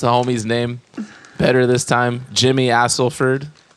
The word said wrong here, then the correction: Astelford: Astelford. – Man.